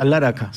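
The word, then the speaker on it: Alla Rakha: ¿Cómo se pronuncia? – Al Araka, sí.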